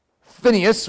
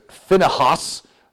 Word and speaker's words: Phinehas: Phineas was typically how we pronounce it. Phinehas could be an accurate way, even though that doesn't sound as good.